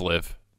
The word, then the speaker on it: LIV: The only thing that I'd say about this LIV Golf League, if we're even saying that correctly, is that it is LIV.